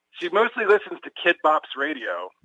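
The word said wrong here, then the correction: Kidz Bop: It's Kids Bop, yeah.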